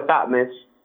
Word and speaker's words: Thotmes: That's E-L-G-I-N Bokhari, B-O-K-A-R-I, T for Thotmes, Smith. Thotmes is spelled T-H-O-T-M-E-S, and Smith is the most generic way of how you smell spelled Smith, is S-M-I-T-H.